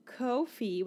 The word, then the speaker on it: Ko-fi: So you can go to Ko-fi, which is spelled K-O-F-I. Say it with me. – K-O-F-I.